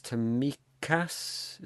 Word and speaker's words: Tsimikas: I'm thinking of the fella Kostas Tsimikas.